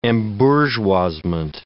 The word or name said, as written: embourgeoisement